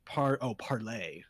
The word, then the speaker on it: Parler: Parler, pronounced parlay. – Parlay? – So, 1600s and they think they're pirates. – Yarg. – As in the French word meaning to speak, was founded by John Matz, libertarian software.